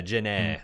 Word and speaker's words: Genet: It's Janae, I think is her name. – Yeah, it's G- Gan. Yeah, that's it's Janet because I it's I pronounced G E N E T. But yeah, Janet.